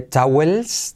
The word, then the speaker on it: towels: No, no se dice towels, sino towels. Towels, con la L suavecita y la W como una U, ¿vale?